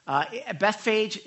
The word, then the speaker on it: Bethphage: Bethphage, that's how I call it. It's actually pronounced differently in Hebrew, but that's how I've always learned it, so that's how I'm going to say it.